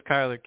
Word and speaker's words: Kyler: It's Kyler, K-Y-L-E-R Smith.